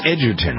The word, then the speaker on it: Egerton: Taron Egerton. It's Edgerton, right? – With correct. – Hard G or soft G? Soft G. – Edge, yeah. – Edge. Edge Edgerton.